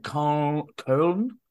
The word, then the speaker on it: Köln: They played Köln.